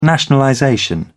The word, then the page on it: nationalization: British English